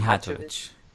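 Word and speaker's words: Mijatović: Mijatovic. Mijatovic.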